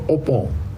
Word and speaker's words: Obon: 所以我现在这搞清楚了，就是这个节呢，日本也过，日本呢不叫做盂兰节，日本叫做浴盆节，日文的读音呢叫做 Obon，浴盆这个正式的节日，日本在明治维新以后就改成一个正式的这个一个节日，是放假的啊。